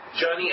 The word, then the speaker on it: Yanni: Like Memphis, with whom they were the huggingest of friends, the kitties, Yanni and Yanni, pronounced Johnny and Yanni, pronounced with a J and a Y sound, to differ, though nobody knew which was which.